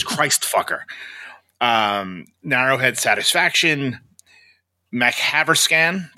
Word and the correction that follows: MacTaverscan: Did I say that right? – MacTaverscan, I believe. MacTaverscan.